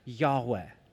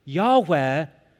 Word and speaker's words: Yahweh: Most likely pronounced Yahweh, which simply means the one who is. Yahweh, the one who is, in our English Bibles, rendered Lord, L-O-R-D, in all caps.